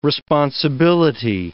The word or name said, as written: responsibility